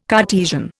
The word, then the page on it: Cartesian: Indian